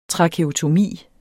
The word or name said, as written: trakeotomi